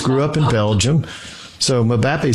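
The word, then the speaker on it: Mbappé: That's Mbappe.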